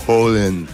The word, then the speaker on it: Haaland: Me dijo, es Haaland. Como una doble Haaland.